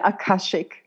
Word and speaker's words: Akashic: Is it Akashic? Is that how you say it? – Yeah, Akashic.